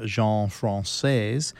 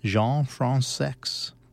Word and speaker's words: Jean Françaix: Now, lots of folks prefer pronouncing his name Jean Françaix, and that became the fashionable way of pronouncing his name for obvious reasons, but he insisted it was really Jean Françaix.